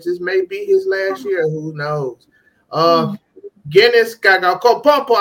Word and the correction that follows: Antetokounmpo: I can't never say his name right. I'm just going to call him the Greek. – Antetokounmpo. – Okay. Guinness Antetokounmpo.